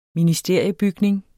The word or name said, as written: ministeriebygning